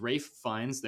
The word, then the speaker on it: Ralph Fiennes: It stars Ralph Fiennes.